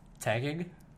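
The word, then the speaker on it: Taguig: Tagig. Like to gig.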